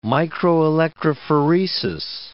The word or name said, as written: microelectrophoresis